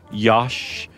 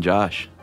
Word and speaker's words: Josh: Especially if you say it in other ways like Yosh or Hosh. – Yeah. – There's all sorts of ways you can say Josh, but really the only right way to say it is Josh.